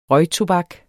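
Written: RØGTOBAK

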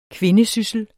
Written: kvindesyssel

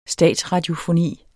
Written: statsradiofoni

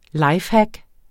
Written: lifehack

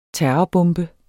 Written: terrorbombe

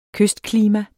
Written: kystklima

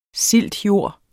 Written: siltjord

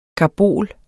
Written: karbol

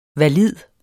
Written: VALIDT

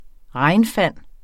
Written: regnfang

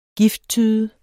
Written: gifttyde

